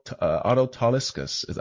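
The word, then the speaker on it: Tolischus: Taliskas. I think I'm pronouncing his name right. And Malicious. You said like delicious, like it rhymes with delicious.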